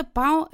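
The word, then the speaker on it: pão: Вообще-то «пау» – это хлеб, но его надо произносить с носовым звуком, которого нет в русском.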